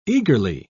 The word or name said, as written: eagerly